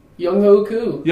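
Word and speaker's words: Younghoe Koo: Young Hoku.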